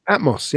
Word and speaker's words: Atmos: Am I saying that right? Atmos? – Atmos, yeah.